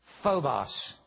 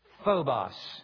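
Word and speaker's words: Phobos (φόβος): I'm going to pronounce it only because you're going to hear the English word in it. Phobos. Phobia. Phobos.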